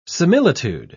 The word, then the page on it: similitude: British English